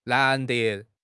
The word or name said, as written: 难得